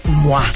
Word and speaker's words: Moi: Pero es muy fácil, es revista MOA, que se escribe M-O-I. – Y acuérdense que cuando la vean, ustedes digan ¡Mua! Y aparte, ahorita buscamos el número de ¡Mua!